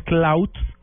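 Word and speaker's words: Klout: Cloud, lo que pasa es que la pronunciación es, es muy difícil hacer esa, esa pronunciación, pero es cloud con T y con K,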